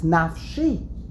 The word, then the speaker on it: Nafshi: It's spelled Nafshoi, and the pronunciation is Nafshi.